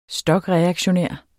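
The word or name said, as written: STOKREAKTIONÆRT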